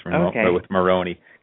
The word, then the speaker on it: Moroney: It's a little bit of an Irish descent, actually, with Moroney. Kind of sounds like Italian sometimes, but it's actually Irish.